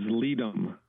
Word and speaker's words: Ledum: Okay, so the name of the medicine is Ledum.